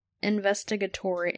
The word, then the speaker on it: investigatory: In 2007, the Advanced Aviation Threat Identification Program was a secret investigatory. How do you say that? – I think you were right the first time. Investigatory.